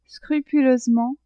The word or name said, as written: scrupuleusement